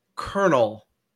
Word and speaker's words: colonel: Colonel. There's no R in it. – I had a hard time with that as well, especially as a non-English speaker. It's like colonel.